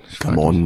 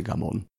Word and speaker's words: Gamon: Gamon eigentlich, aber sie sagt jetzt mittlerweile selber Gamon, weil das niemand richtig aussprechen kann. – Also ist es auch nicht der Werner Amon oder Werner Amon? – Nein, ist ja der Amon, weil er ein Ostösterreicher ist. In Vorarlberg heißt sie Gamon.